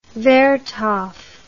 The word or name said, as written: vertov